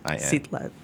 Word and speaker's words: Citlalin: C-I-T-L-A-L-I-N. – Sitlalin.